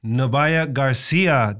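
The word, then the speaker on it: Nubya Garcia: Nobaya Garcia, did I say your name right? – You did.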